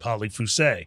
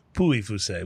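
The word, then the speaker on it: Pouilly-Fuissé: I think it's the only wine she knew, but she would order it and call it Polly Fusé. Mm-hmm. And so that got drilled into my brain. But that would also be pronounced Puy Fusé, wouldn't it? – It would.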